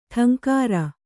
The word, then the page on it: ಠಂಕಾರ: ṭhaŋkāra